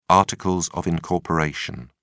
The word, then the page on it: articles of incorporation: British English